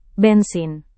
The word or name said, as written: bensin